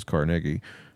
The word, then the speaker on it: Carnegie: And old man Carnegie, which is actually how you pronounce his name, is Carnegie,